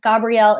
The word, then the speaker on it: Gabrielle: So I use the name Risa Gabrielle in my sleep work. That's actually my first and middle name. And Gabrielle is spelled G-A-B-R-I-E-L-L-E.